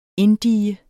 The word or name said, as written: inddige